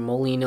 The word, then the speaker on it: Moline: So one guy that is already here, he was there last year as a freshman, Illinois guy from Moline, Illinois.